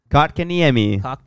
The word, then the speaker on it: Kotkaniemi: Kotkaniemi. – Kotkaniemi. – You're putting too much emphasis on the cock, man. You go cocked. – I know. Kotkaniemi.